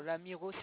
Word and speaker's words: Lamirault: Alors, l'Amiraux, ça s'écrit LAMIRAULT.